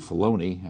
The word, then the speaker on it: Filoni: It's actually Filoni. I keep mispronouncing his name.